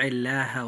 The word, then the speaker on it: الله: فإذا جاءت اللام في لفظ الجلالة بعد حرف مكسور فيجب أن ترقق هذه اللام عند النطق، فننطقها هكذا. – ومن يطع الله ورسوله.